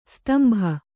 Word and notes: ಸ್ತಂಬ: kannada; stamba

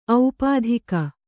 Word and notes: ಔಪಾಧಿಕ: aupādhika

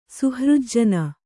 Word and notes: ಸುಹೃಜ್ಜನ: kannada

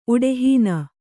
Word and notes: ಉಡೆಹೀನ: kannada

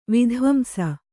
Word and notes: ವಿದ್ವಾಂಸ: vidvāmsa; Kannada

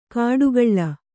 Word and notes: ಕಡುಗಳ್ಳ: kaḍugaḷḷa

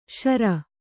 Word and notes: ಶರ: śara

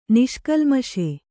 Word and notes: ನಿಷ್ಕಲ್ಮಷ: niṣkalmaṣa

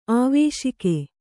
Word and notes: ಆವೇಶಿಕೆ: āvēśike; kannada

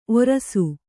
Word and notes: ಔರಸ: kannada

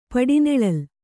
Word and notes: ಪಡಿನೆಳಲು: Kannada

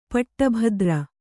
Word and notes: ಪಟ್ಟಭದ್ರ: paṭṭa bhadra

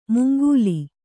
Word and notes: ಮುಂಗೂಲಿ: mungūli; Kannada

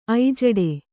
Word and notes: ಐಜಡೆ: kannada

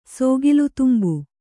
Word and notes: ಸೋಗಿಲುತುಂಬು: sōgilu tumbu

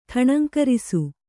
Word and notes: ಠಣಂಕರಿಸು: Kannada

